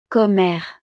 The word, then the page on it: commere: frances